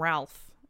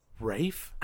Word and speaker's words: Ralph: And then I think he might be in trouble because his name is spelled like that. But I think I remember reading somewhere that it's not pronounced that way. – Do people call him that? Because see, I always get confused about that. So it's Ray. – It's like Rafe.